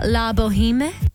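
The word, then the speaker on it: La Bohème: Baz Luhrmann and Catherine Martin's original neon red L'Amour sign for Opera Australia, La Boheme. Am I saying that correctly? – Yeah.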